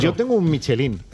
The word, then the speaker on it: Michelin: A ver, aquí en la Península Ibérica hacemos Michelin. – Sí, claro. – Claro, pero la realidad, tú eres un chef internacional. – Ya, pero ¿tú cómo lo pronuncias? – Me cuesta ser tan tontorraco, ¿no? Michelin. – Michelin. – Yo digo Michelin.